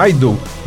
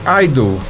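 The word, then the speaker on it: idle: E o adjetivo tem exatamente a mesma pronúncia de idol, que é ídolo em inglês.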